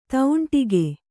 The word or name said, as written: ತೌಂಟಿಗೆ